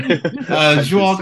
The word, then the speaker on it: João: It's João? – João. It's impossible to pronounce. Don't worry. – João Gonzalez.